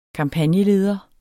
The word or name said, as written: kampagneleder